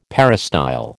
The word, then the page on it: peristyle: American English